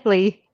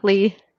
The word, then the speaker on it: Leigh: Leigh. – Okay. Thank you very much for joining. Huh? Close? Well, what is the correct pronunciation? I'm sorry. – Leigh.